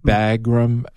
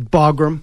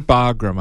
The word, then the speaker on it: Bagram: Bagram Air Force Base? – Yeah, right? – Bagram. – Bagram.